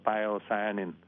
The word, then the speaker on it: pyocyanin: Cái chất màu xanh đó nếu đi vào chi tiết thì nó tên là pyocyanin, tiếng Anh đọc là pyocyanin, hay là pyoverdin.